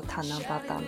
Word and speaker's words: 七夕: 为什么日本的这个七夕，它中文汉字写成七夕，但是它读作塔那巴达呢？